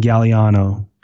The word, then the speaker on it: Gagliano: The other one would be Mike and Ann Galliano, and it's spelled like Gagliano.